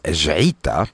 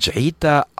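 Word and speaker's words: Jeita: The track was called Jaita, and I'll spell that for you. It's J-E-I-T-A, and it's called Jaita Upper Cave.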